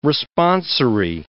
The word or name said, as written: responsory